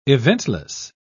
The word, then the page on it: eventless: British English